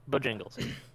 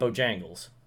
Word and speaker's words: Bojingles: Am I saying that right? – Yes, Bojingles. – Not Bojangles. – Nope, jingle.